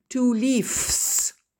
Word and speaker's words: leaves: Tous les noms terminés par F ou FE font le pluriel en VES qui se lit VZ. Parce qu'il est impossible après la lettre S de prononcer la lettre S. Ça donnerait sinon one leaf, two leaves. Donc, pour faciliter la prononciation, c'est logique, on va mettre VES et on va dire one leaf, two leaves.